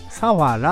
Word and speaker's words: 佐原: 佐原は佐藤さんの佐に原っぱの原と書いて佐原なんですね。佐原じゃなくて佐原と読むんですが、こちらのインターチェンジの近くには香取神宮が鎮座しております。